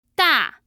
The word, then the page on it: da: 4